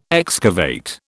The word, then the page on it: excavate: British English